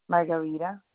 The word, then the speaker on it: Margarita: Margarita.